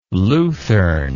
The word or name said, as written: luthern